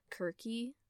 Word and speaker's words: Circe: So, today I will be doing a book review about Circe. And just going to pause here and say that that is not the actual Greek pronunciation of the name. The way that the Greeks would pronounce it is Kirky.